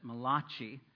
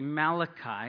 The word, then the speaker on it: Malachi: I know you Italians here pronounce that Malachi, but it is really Malachi.